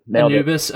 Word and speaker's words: Anubis: Anubis.